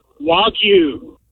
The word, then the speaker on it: Wagyu: Wagyu. – What? – Wagyu. – What? – Wagyu.